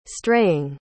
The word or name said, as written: straying